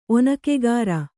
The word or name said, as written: ಒನಕೆಗಾರ